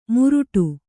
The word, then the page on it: ಮುರುಟು: muruṭu